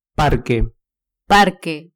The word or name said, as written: parque